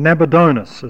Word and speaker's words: Nabonidus: Well, if we remember that father there is just talking about ancestor, and that the father of Belshazzar was Nabonidus, or Nabonidus as some choose to pronounce it.